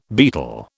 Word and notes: betel: British English

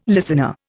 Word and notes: listener: Indian

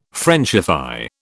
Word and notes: Frenchify: British English

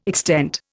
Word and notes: extent: Indian

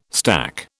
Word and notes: stack: British English

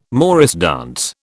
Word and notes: morris dance: British English

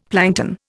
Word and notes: plankton: Indian

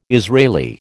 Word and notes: Israeli: American English

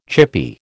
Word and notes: chippy: American English

